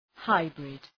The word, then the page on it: hybrid: {‘haıbrıd}